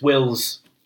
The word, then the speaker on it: Whills: And it's Wills, I think it's spelled like W-H-I-L-L-S.